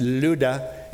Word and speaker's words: Lydda: I know it looks like Lydda or Lydda, but it's actually pronounced Lydda. There's a little accent there, which I can't do.